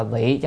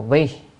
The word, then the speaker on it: vị: Tiếp theo vị giáo bồ tát, chữ này đọc là vị chứ không phải là một vi, vị là vì, còn nếu đọc vi là vị giáo bồ tát là vì giáo, giáo là giáo giáo hóa ha.